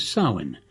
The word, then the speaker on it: Samhain: This week we will celebrate the most famous of the pagan or neo-pagan holidays, Samhain, better known as All Hallows' Eve, or if you prefer, Halloween.